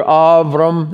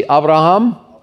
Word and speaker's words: Avram: Father, and sing it with the first name, Father Avram. That's how you should pronounce it. Pronounce Abram properly. It's not a B, but a V in the Hebrew. Say it with me, Avram. Avram, now say Avraham.